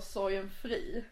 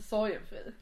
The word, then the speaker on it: Sorgenfri: Hörde du att jag sa sorgenfri? – Ja. Och inte sorgenfri.